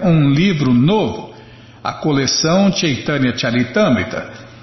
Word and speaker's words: Chaitanya: Ninguém conseguia pegar a pronúncia. Haviam ouvido falar de. Muita gente lê Caitânia, né? Haviam ouvido falar de Chaitanya, certamente, mas não desse novo livro.